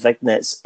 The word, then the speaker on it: vignette: The Smoking Guns, who debuted in WWF after a long series of vignettes. I can never pronounce that word correct. How do you say it properly? – I say it kind of French, vignette.